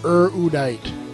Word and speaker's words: Erudite: It's supposed to be Erudite.